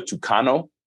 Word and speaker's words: Tucano: Now, I think you said your boat was called the Tucano. Am I saying that correctly? – Mm-hmm. – Describe that,